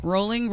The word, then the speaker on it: Rowling: Rowling rhymes with bowling.